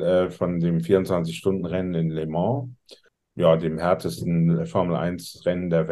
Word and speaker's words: Le Mans: Ich fand Französisch immer doof, deswegen hoffe ich, dass ich es richtig ausspreche. – Le Mans. – Ja, das stimmt, glaube ich. so wird, wird, es auch in dem Film äh, ausgesprochen. In, ja. in, in, der Synchronfassung jedenfalls.